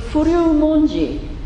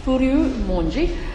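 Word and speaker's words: Furyu Monji (不立文字): So, Furyu Monji, so hier habe ich ja die Aussprache klar markiert, Ji, nicht I, sondern Ji, Furyu Monji.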